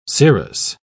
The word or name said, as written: cirrus